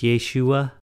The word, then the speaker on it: Yeshua: For Jesus to be pronounced in the proper Hebrew language is Yeshua, which means the Lord saves.